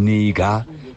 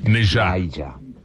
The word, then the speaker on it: Niger: You're looking at Burkina Faso, Mali, and the country which is named Niger, but we pronounce it wrongly and say it Niger.